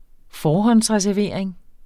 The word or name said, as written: forhåndsreservering